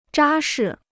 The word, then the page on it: 扎实: zhā shi